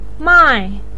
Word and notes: mai4: pinyin

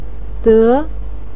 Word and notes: de2: pinyin

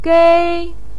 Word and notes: gei1: pinyin

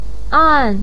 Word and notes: an: 4

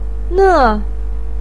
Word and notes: ne4: pinyin

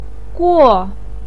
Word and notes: guo4: pinyin

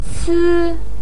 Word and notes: ci1: pinyin

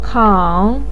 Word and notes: kao3: pinyin

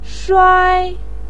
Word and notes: shuai1: pinyin

